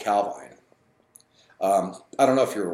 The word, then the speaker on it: Calvine: The Calvine UFO, Calvine, people pronounce it different ways. Nick said it's Calvine.